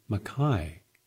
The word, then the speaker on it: maqui: It is called the macai, but it's spelled M-A-Q-U-I.